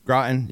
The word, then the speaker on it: Groton: Our show researcher, Matthew, he was in the Navy for a while, went to submarine school in Groton. Am I saying that right? – Groton, yeah.